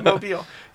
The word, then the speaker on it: Mobile: Mobile. Hey, you said it right. Most people don't. Mobile.